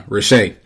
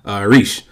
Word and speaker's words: riche: I know the last episode, I was pronouncing it Riche. Clearly, my French is tragic. It is pronounced Reesh.